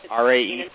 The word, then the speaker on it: RAE: And then, as I said, what we'll do is when we use ray, we'll just define it then with... – You see, you just pronounced it ray, not R-A-E. – R-A-E, I'm sorry. R-A-E.